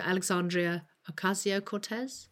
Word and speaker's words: Alexandria Ocasio-Cortez: And I'm going to get her name wrong. You'll tell me how to say it properly. So Alexandria Ocasio-Cortez? – Yeah. – Only with a proper accent.